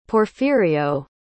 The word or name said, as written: Porfirio